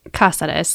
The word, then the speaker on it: Cazares: I'm going to mispronounce your name. I know Cortney really well, but mm-hmm. I would pronounce your name Cazares. How would your husband, Nate, pronounce his name? – Cazares.